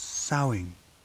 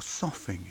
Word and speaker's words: soughing: The sound is soughing, or soughing, if you prefer. The OED allows both pronunciations. The latter is more onomatopoeic.